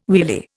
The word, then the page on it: wheelie: Indian